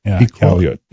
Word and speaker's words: Iqaluit: Iqaluit.